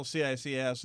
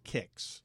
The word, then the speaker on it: CICS: And they, you don't like pronounce that, right? It's still CICS. It's not like KIX. – Occasional people say KIX, but mostly CICS.